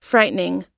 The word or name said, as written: frightening